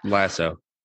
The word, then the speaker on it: Lasso: Lasso.